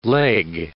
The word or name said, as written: leg